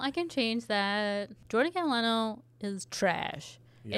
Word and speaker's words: Catalano: Catalano.